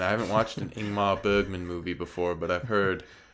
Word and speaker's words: Ingmar Bergman: Is that how you say his name? Ingmar Bergman. – Ingmar Bergman. – Yes. like Iceberg.